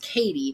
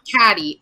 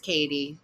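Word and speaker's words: Cady: and so I know that that is pronounced Katie, yeah, not Caddy. And I said Caddy, and she went, "It's Katie," and I was like, "Oh, cool."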